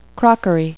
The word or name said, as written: crockery